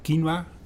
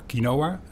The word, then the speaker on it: quinoa: Yeah, what we provide is quinoa. That's the pronunciation from Latin America, or quinoa, because now we grow it in the Netherlands.